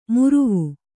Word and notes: ಮುರುವು: muruvu

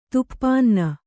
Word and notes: ತುಪ್ಪನ್ನ: tuppanna

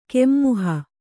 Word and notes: ಕೆಮ್ಮುಹ: Kannada

